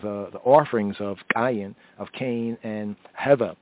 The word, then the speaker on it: Hevel: Hevel in the Hebrew, Hevel. Some modern Yehudi and Jews would say Hevel, right? Or Havel, Havel. They'll pronounce it as Havel. So the distinction between Abel and the word for vanity is a nuance of pointing. For Abel, Habel, Habel, or Havel, and for vanity, Hevel, or Hebel, Hebel.